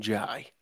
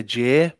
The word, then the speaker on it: Jai: I think you said his name right as well, because I pronounce him Jai. Some people say Jay, but I do think it's Jai. – No, it's Jai.